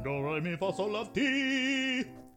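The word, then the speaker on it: Domrémy: I think it's Domremy. Thank you. – It might be Domremy. – There's an M in there though. Domremy. – Domremy.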